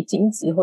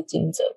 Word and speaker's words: 惊蛰: 对啊，这两个发音其实都可以啦，就是大家习惯问题，惊蛰或是惊蛰都 OK。